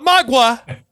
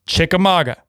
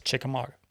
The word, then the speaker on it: Chickamauga: He said Chicka-freaking-mauga. Not magua. Maga. Chickamauga. Pass that on to Marty. I don't care. He needs to know. It's Chickamauga. not magua.